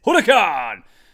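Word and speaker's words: Huracán: I think that's how you pronounce Huracan. – It. That's correct emphasis. Huracan!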